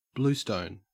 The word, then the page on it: bluestone: Australian English